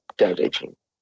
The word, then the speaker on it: Tao Te Ching: Go on YouTube, T-A-O space T-E space C-H-I-N-G, Tao Te Ching. It sounds like a D, but it's actually a T. Just the way it's pronounced.